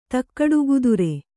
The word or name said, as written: ತಕ್ಕಡುಗುದುರೆ